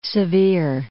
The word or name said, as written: Sevier